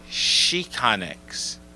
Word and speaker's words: Xicanx: Pronounced the same way? Oh, it's pronounced Chicanx. – Mm-hmm. – Chicanx. – Because the X is a different sound in Spanish.